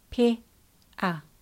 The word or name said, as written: pea